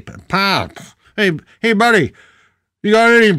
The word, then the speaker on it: Pabst: William's daughter, Hilda, married Gustav Pabst. Am I saying it right? Yes.